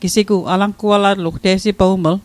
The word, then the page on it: alangualatl: Mi'gmaq